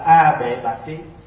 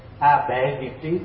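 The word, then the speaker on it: A Bệ Bạt Trí: Mà đủ ba cái này thì được gọi là A Bệ Bạc Trí. Có khi đọc là A Bệ Việt Trí cũng có, nhưng mà cái đó là âm lại thôi, âm từ tiếng Phạn.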